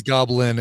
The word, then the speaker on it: Goblynne: Her name is Goblin, and it's spelled Goblynne. Like if her name was Lynn, she just added Gob to the beginning, so she's Goblin.